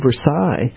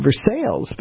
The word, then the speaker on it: Versailles: Well, and this is one of the traumas from my junior high school of the Treaty of Versailles, and I pronounced it Versailles, because I'd only seen it, I'd only seen it written, I'd never heard it pronounced. And Mr. Mason laid me out in lavender and said, people died there. It's Versailles.